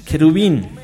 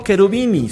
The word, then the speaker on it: Cherubini: Oye, por cierto, se pronunciaba, y ahí nos sacamos la duda los dos, que sería Cherubini. Lorenzo Cherubini, si Cherubini. – Cherubini sería la pronunciación de ese Cherubini. En italiano la sería Cherubini, ¿no es cierto?